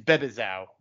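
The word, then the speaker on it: Bebezão: Bibizao. B-B-Z-A-O. – It's Bibizao. Bibizao. How you say it.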